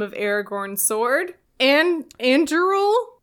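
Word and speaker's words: Andúril: they pronounce it in the movies, and I know how Rob Inglis pronounces it, but they're the same. It's like, it's Anduril, yeah.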